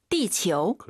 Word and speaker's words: 地球: 치유하지 마시고 아유 사이에 오 넣어서 지구 발음 다시 한번. – 地球.